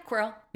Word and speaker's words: Quirrell: Quirrell. Am I saying that right? – Yeah. Quirrell.